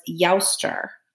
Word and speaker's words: Eostre: They honored their spring goddess whose name was Yaustar. Now let me spell it for you. E-O-S-T-R-E. – Right.